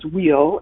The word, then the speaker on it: weal: And the two acronyms that came up for me, the spelling of the word well is W-E-A-L, which has its roots in Old English, and is actually, common pronunciation is wheel, and the way the energy of that title always has been for me is Living Well, that pronunciation.